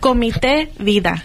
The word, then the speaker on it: Comité Vida: But how do you pronounce it? C-O-M-I-T-E. Vida. How would you pronounce it? – Comité Vida.